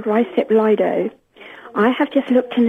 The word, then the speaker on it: Lido: And the I is pronounced an I with a colon after it, as in the word me. – I. – Therefore, the correct pronunciation is Lido.